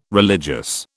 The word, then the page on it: religious: British English